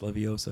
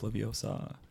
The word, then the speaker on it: Leviosa: It's not pronounced Leviosa. It's pronounced Leviosa.